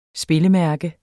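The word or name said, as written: SPILLEMÆRKER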